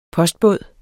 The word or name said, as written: postbåd